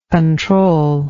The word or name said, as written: controlless